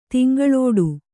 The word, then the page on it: ತಿಂಗಳೋಡು: Kannada